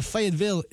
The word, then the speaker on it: Fayetteville: Nel 75 a Fayetteville in Arkansas, così si pronuncia, Bill Clinton sposa Hillary Rodham, 29enne lui e quasi 28enne lei.